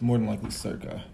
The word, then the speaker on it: circa: King S reigned from 1113 to, how do you say that? C 1150? What is that? Is that Christ? – It's probably circa. – Oh, okay. Wow. To circa 1150.